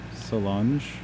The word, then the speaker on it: Solange: And right now we have Losing You by, I struggle to pronounce her name, Solange? – Oh, yeah, that's it.